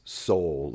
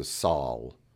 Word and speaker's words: Sol: And you found where it is not pronounced soul, like S-O-U-L. It's more pronounced like saw sound, so Sol.